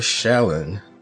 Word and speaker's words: Shalon: And last but not least, we have Shallon.